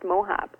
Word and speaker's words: Mohaupt: And Mohaupt, Mohaupt, is my last name, but it's phonetically pronounced Mohaupt.